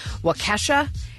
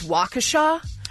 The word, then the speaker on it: Waukesha: I pronounced the city in Wisconsin as Waukesha. It is Waukesha.